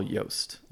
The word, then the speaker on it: Jobst: There was a video that came out like a week and a half ago, maybe 2 weeks ago, by Carl Jobst. And Yost. It's on YouTube. – Carl Jost. – Jost. Okay. And forgive my pronunciation.